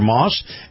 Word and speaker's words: Maus: Now, Moss Nissan is spelled M-A-U-S for Moss.